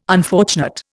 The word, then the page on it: unfortunate: Indian